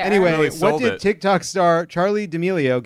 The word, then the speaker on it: D'Amelio: What did TikTok star Charlie D. Emilio get named after? Did I say that right? – What? D'Amelio.